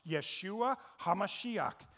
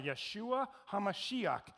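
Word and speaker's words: Yeshua HaMashiach (ישוע המשיח): Jesus the Messiah in Hebrew is pronounced Yeshua HaMashiach. Jesus the Messiah, Yeshua HaMashiach, our salvation, the anointed one.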